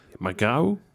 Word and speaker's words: Magrão: Magrão. – Nou, perfect. – Echt waar? – Ja, Gerson Magrão, want zo spreek je het uit.